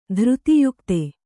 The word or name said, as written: ಧೃತಿಯುಕ್ತೆ